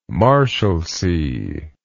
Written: Marshalsea

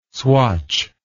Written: swatch

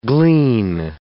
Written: glean

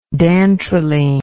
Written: dantrolene

